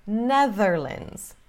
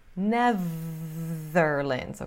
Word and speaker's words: Netherlands: Mas, em inglês, they, normal, the, normal, Netherlands. Netherlands, ok? Com esse TH vozeado, como se fosse ZZ, língua presa.